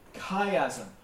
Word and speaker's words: chiasm: Chiasm.